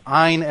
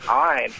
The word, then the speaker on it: Ayn: And she told me it was Ayn as in Schwein. So Ayn. – Oh. Ayn Rand.